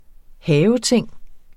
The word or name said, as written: haveting